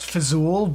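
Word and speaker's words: Fzoul: I think it's actually supposed to be pronounced Fizul, but it's foul with a Z in it.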